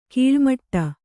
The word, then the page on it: ಕೀಳ್ಮಾಡು: kannada